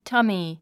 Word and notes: tummy: tʌ’mi; タミィ

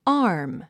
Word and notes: arm: ɑ’ː r m; アーム

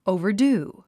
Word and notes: overdo: òuvərdúː; オゥバァドゥ

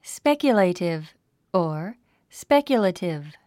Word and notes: speculative: spékjulèitiv; スペキュレイティブ

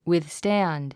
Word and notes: withstand: wiðstǽnd; ウィズスタァンド

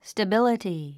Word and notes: stability: stəbíləti; スタビラティ

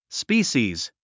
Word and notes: species: spíːʃiːz; スピーシーズ

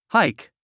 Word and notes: hike: háik; ハイク

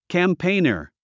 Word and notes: campaigner: kæmpéinə r; キャンペイナァ

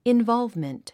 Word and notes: involvement: invάlvmənt; インヴォルブメント

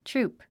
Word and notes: troop: trúːp; トゥループ

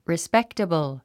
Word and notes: respectable: rispéktəbl; リスペクタブル

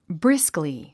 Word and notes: briskly: brískli; ブリスクリィ

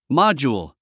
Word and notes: module: mάdʒuːl; モォジューゥ

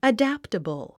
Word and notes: adaptable: ədǽptəbl; アダプタブル

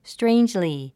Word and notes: strangely: stréindʒli; ストレインジリィ

